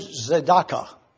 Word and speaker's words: tzedakah: The way it's spelled is T-Z-A-D-E-Q-A-H. I always like to take Hebrew words and put them where I can pronounce them and then discuss them. So I've taken this word so that you can know how to pronounce it. Tzedakah. Z-E-D-A-K-A-H.